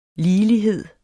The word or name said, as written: ligelighed